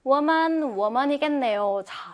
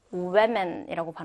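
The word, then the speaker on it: women: 쌤, 그럼 얘도 woman, woman이겠네요. 근데 얘는요. 발음이 조금 달라서 여러분이 주의를 해 주셔야 됩니다. 자, 보세요. 여성 한 명일 때는 우먼. 자, 쌤이 어떻게 발음했어요? 우먼. 우 발음했죠? 우. 자, 근데 여성 여러 명일 때는요. 우에맨이라고 발음해요. 우에맨. 자, 방금은 우먼. 여기는 우에맨이라 그랬죠? 그러니까 좀더위 발음이 납니다.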